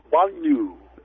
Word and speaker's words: banlieue: Banyu. The L is kind of like a L-Y combination. – Okay. – Yeah, Banyu Blue. And Banyu in French, B-A-N-L-I-E-U-E, Banyu, is the French equivalent of suburb.